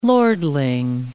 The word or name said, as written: lordling